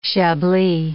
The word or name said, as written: Chablis